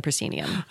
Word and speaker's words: proscenium: Currently can be seen in the Guthrie's The Royal Family, which runs, tell me how to say the word, proscenium.